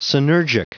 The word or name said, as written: synergic